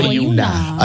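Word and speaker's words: Boiuna: Yeah, I can spell Boyuna.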